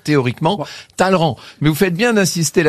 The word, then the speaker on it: Talleyrand: Ça s'écrit Talleyrand, mais ça se prononce Talleyrand. Alors, c'est ce qu'on appelle les prononcés de cour.